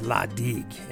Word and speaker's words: La Digue: is La Digue, and that is pronounced correctly.